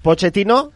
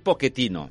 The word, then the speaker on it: Pochettino: Segons un italià, em va dir una vegada que està dit Pochettino. – Ah. – Perquè és un cognom italià. – Ah. – Però clar, si el pronuncies en castellà, en aquest cas ell és argentí, és Pochettino. – Italià, sí. – Italià és Pochettino.